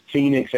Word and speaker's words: Fenix: There is one person who stands out, and that's, you know, Phoenix Ammo. You guys familiar with Phoenix? – Oh, no, not, no. – And it's spelled F-E-N-I-X Ammo.